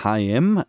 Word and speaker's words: Haim: Die nennen sich H.I.M., also wird H.I.M. ausgesprochen, aber Heim geschrieben, H-A-I-M.